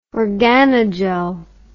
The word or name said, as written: organogel